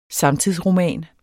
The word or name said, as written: samtidsroman